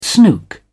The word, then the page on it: snook: English; British English